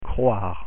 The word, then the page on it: croire: French